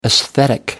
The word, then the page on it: esthetic: American English